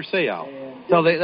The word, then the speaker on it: Seau: That time, number 45, Adam Junior Seau. That's how they pronounce it in the islands.